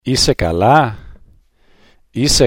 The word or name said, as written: eisai kala